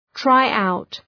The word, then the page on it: tryout: ‘traı,aʋt